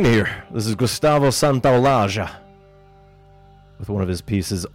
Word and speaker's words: Santaolalla: And I've played some Santaolalla. Always have to pronounce it the Argentine way, since that is where he is from. For you conventional Spanish speakers, that is Santaolalla.